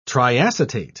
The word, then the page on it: triacetate: British English